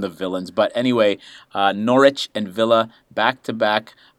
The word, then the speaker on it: Norwich: I don't know if you know, but it's pronounced Norwich, not Norwich.